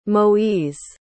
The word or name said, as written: moise